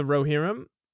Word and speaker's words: Rohirrim: Ride of the Rohirrim? – Go ahead.